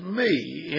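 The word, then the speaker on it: me (מי): And that is 43:10 in the Strong's, and it's pronounced as me, M-E, me, in the Hebrew.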